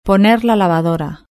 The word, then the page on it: poner la lavadora: European Spanish